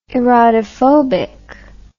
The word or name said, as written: erotophobic